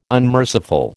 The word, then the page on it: unmerciful: American English